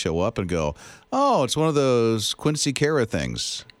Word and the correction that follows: Quinceañera: I actually called my daughter last night and I was like, let's make sure I can say it. Quinceañera.